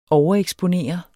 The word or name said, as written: overeksponere